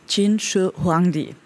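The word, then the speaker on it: Qin Shi Huangdi: It's pronounced Jin Shi Huangdi. Jin, that means that the people of Jin and the region Jin, and also the dynasty. And Shi is the first, and Huangdi is the emperor.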